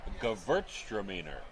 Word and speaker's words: Gewürztraminer: Gewürztraminer.